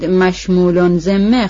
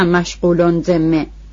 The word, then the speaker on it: مشمول ذمه: پانویس: این کلمه باید مشمول ذمه خوانده شود نه مشغول ذمه، یعنی آنطور که عامه مردم میگویند، زیرا مشغول با غین در اینجا غلط است و مشمول با میم باید نوشت و تلفظ کرد.